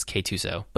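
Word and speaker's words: K-2SO: Yeah, the official pronunciation guide is K2SO, but it was weird.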